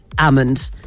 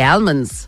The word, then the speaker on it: almond: I would have said, I didn't know until like a couple of years ago that almond. I used to say almonds, but most people do say almond because it is spelled with an L, but it's a silent L apparently.